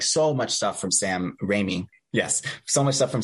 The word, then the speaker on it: Raimi: Please correct me if I'm saying that wrong. Raimi, thank you, who we're going to be so excited to talk about around the time of Multiverse of Madness, the sequel to Doctor Strange.